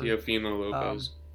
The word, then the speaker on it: Teofimo Lopez: Teofimo Lopez or whatever. – Teofimo Lopez.